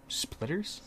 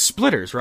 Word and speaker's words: Splitters: the Office of Science and Industry Splinters? I'm sorry. – No, wait, what? Splitters? – It's splitters, right? S-P-L-I-T-T-E-R-S. There's no N in there, so it's splitters.